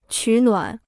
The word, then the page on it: 取暖: qǔ nuǎn